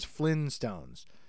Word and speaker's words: Flintstones: I always thought it was Flintstones. I've been pronouncing it without the first T my whole life.